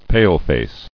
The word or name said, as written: Paleface